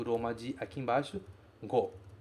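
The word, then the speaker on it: go (五): Aqui o kanji se pronuncia go.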